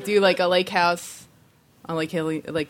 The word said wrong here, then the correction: Hylia: Hylia, yeah. I always said it wrong as a kid.